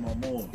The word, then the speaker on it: Momoa: Jason Momoa.